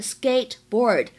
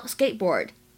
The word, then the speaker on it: skateboard: 然后呢，滑板这个单词，大家平时可能会读成是 skateboard，但是一般来说呢，这里会有一个完全失去爆破的现象，这个 t 的发音呢只做口型，并没有把声音发出来，会变成 skateboard skateboard。